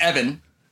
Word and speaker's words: Even: Evan, spelled E-V-E-N, but pronounced Evan.